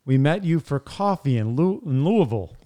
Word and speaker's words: Louisville: Yeah, it's clearly Louisville. – Louisville.